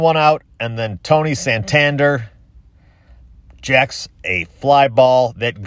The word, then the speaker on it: Santander: Santander.